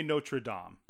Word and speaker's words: Notre Dame: How do you say it? – I believe we should say Notre Dame. – Okay, and Hunchback of Notre Dame. – Unless he's playing football.